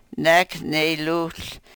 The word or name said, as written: nek ne-luhl